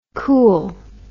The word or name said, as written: kool